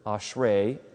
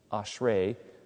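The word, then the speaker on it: ashrei (אשרי): And that's probably a better translation, because the Hebrew word, ashrei, A-S-H-R-E, with a line over it, it's a long E, ashrei, means happiness.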